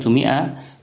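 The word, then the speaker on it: مائة: كلمة مئة تكتب ميم أليف همزة تاء مربوطة، أو 200 ميم أليف همزة تاء يا نون. الألف فيها تكتب ولا تلفظ، ولعلهم كانوا يكتبونها للتفريق بين كلمة مئة ومنه، لأنها تشتبه في اللفظ، أو في عفوا في الشكل.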